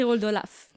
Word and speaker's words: Oldelaf: comment ça s'écrit Old Olaf? – Ah bah, comme tu le prononces. O-L-D-E-L-A-F.